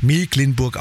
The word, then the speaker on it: Mecklenburg: Übrigens für alle Skeptiker, die immer noch sagen, ja, wieso Mecklenburg, das wird doch aber mit CK geschrieben. Gleich 7.10 Uhr erklärt uns Björn Berg vom Kreis Agrarmuseum Dorf Mecklenburg im Landkreis Nordwestmecklenburg, warum es dennoch Mecklenburg ausgesprochen wird.